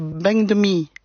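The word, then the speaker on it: bánh mì: En vietnamien, le P, on ne peut pas le prononcer, donc on dit B. Donc si on prononce avec l'accent du Nord, on dirait banh mi, banh mi.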